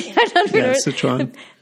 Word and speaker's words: Szechuan: Szechuan.